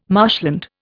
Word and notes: marshland: Indian

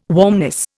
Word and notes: warmness: Indian

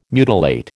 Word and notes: mutilate: American English

